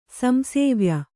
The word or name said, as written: ಸಂಸೇವ್ಯ